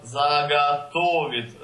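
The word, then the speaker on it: заготовить: Hier spricht man einfach Zagatovit. Also ich glaube mehr A.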